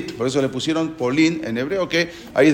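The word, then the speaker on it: Polín (פולין): Incluso en hebreo, Polonia, no sé si saben cómo se dice Polín. – ¿Cómo? – Polín. Polín. Polín, así se dice en hebreo.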